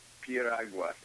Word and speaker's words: piragua: It's a summertime treat called piragua, and it's spelled P-I-R-A-G-U-A. – Oh, so it's like pure agua.